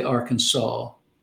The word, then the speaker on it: Arkansas: Well, it's actually an Indian name. It means downstream people, and the Indians say Arkansas. It's not spelled that way, but if you spell it phonetically the way they spoke it, it's Arkansas.